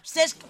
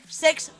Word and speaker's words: Cesc: El grup és comentaristes que no saben pronunciar Cesc Fàbregas i dicen Sex Fàbregas.